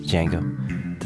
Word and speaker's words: Django: Django, the silent D.